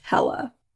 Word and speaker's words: Helle: Okay, I believe it's pronounced Hella. It's Helle.